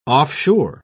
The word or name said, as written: offshore